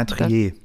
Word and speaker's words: Triet: Also, Anatomie eines Falls ist ein Spielfilm der französischen Regisseurin Justine Triet. Ich hoffe, ich habe sie richtig ausgesprochen. – Ja, Trier. – Trier, ist das, bist du sicher?